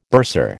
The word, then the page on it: bursar: American English